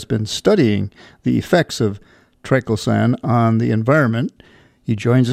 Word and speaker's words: triclosan: Triclosan is the way we like to pronounce it, right. But there are various other possibilities.